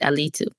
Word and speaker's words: Alitu: I hope I said that right. I said the name right, Alitu. – And, A-L-I-T-U. – Yes. – A-L-I-T-U.